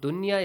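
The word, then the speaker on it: Dunya (دنيا): That the word dunya is not pronounced duya. The rule idgham is not applied here.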